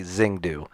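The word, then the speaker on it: Zhongdu: And sorry, I didn't say that right. Zhanfdu is actually Zhengdu.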